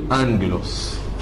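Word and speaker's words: انجلوس (ἄγγελος): اتنين جمله ورا بعض، الاولى بتتنطق نون والتانيه بتتنطق عادي، دي قاعده خاصه بحرف الجمله. فمثلا كلمه ملاك اللي هي بالانجليزي انجل بنكتبها باليوناني كده، جمه جمه ابسلون لامدا وميكرون سيجما، تتنطق انجلوس، تمام؟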